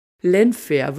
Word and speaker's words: Llanfair: Er wird nicht englisch, sondern walisisch ausgesprochen und ich muss sagen, beim Versuch, das auszubesprechen, da habe ich mich so beömmelt. Googelt das mal. Lenfair wird geschrieben L-L-A-N-F-A-I-R.